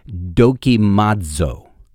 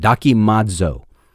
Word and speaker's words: dokimazo: and that word to see fit is dokimazo, that's how you pronounce it, dokimazo, which means that they did not deem God worthy.